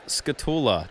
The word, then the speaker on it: Skatula: All right, so our very special guest today is Adam Skatula.